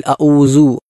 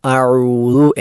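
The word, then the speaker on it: അഴുതു (أعوذ): എന്നാൽ വേറെ ചില ആളുകൾ ദാലിനെ ദായായി ഉച്ചരിക്കും, അഴുസു അല്ലെങ്കിൽ അഊസു എന്ന് പറയാറാണ്, അതും തെറ്റാണ്. അപ്പോൾ തെറ്റായതൊക്കെ നമ്മൾ മനസ്സിലാക്കി ശരിയായ രൂപത്തിൽ മാത്രം ഉച്ചരിക്കാൻ ശ്രദ്ധിക്കണം. അഴുതു എന്നാണ് ശരിയായ ഉച്ചാരണം, ബാക്കിയെല്ലാം തെറ്റാണ്.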